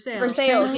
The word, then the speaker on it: Versailles: Say that word, and I'll tell you how you say it in Kentucky. – Isn't it Versailles? – It's Versailles. – Versailles, yeah. – I was like Versailles. – That's how you're supposed to pronounce it. But in Kentucky, yeah. It's Versailles. – That makes sense. – We pronounce all the letters.